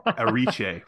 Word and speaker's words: Erice: I'm gonna keep saying his name wrong, Victor Arice, because that's the way I've known it for so long. Ariche, right, is the correct way to say I don't know.